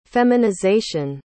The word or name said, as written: feminization